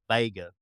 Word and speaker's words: Viega: In the South, it's spelled Viega, but down in the South, we just say Vega.